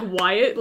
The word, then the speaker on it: Whyatt: Yep, the main character, his name is Wyatt, but no, but don't, it's not spelled like how you think it is. It's spelled W. H Y A T T. Like Wyatt, like Super Y.